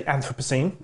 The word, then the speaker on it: Anthropocene: How does one pronounce it exactly? – I say Anthropocene. – Anthropocene. – But the Anthropocene is mainly North Americans. – Okay. So I can say it either way. – Of course you can. Absolutely. Okay. And again, there is no fixed way. So yes, there's a definitely a cross-Atlantic difference in pronunciation.